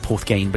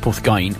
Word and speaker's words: Porthgain: That's spelled Porthgain, but it's pronounced Porthgain.